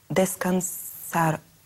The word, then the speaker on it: descansaron: Ellos descansaron. – Descansaron. – Descansaron. – Ellos descansaron. Они отдыхали. Они отдохнули. – Вот это арон, окончание ударения предпоследний слог.